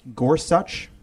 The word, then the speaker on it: Gorsuch: First of all, I did look up how to pronounce it properly, and he did say in his testimony that it's pronounced Gorsuch. That is so frustrating. It's a spondy, right? The emphasis is the same on both the first and second syllable.